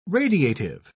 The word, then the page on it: radiative: British English